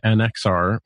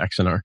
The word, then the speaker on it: Axanar: a Star Trek fan movie called Prelude to Axanar. which um, I'm sorry, I said that wrong. Uh, uh, Prelude to Axanar.